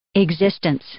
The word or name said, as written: existence